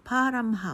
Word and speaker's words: Parham: もう一つ、イギリス南部のウエストサセックス州にある、パーラムハウス、綴りはですね P-A-R-H-A-M、もうイギリスの英語ってこう H 発音しないこと多いんですよね。パーラムハウスですね。